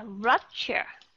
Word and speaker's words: rupture: một cái từ đó ví dụ như là picture hoặc là rupture ok điều thứ nhất nó không phải là short u sound nó là âm gì picture rupture nó là âm gì các bạn nó là schwa sound